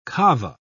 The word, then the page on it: kava: British English